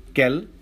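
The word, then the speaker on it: quel: कैल कैल कैल कैल चारों की spellings different हैं लेकिन प्रोनाउंसिएशन ऑलमोस्ट सेम ही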